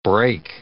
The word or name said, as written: brake